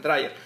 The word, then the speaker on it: Dreyer: No, sé no Dreyer, así se lo pronuncian los, Sí. Así es, Dreyer.